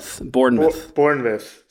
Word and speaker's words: Bournemouth: Well, George wrote this in Bournemouth. Interesting. – Bournemouth. – Sorry. It's spelled with the M-O-U-T-H, but, you know, those Brits.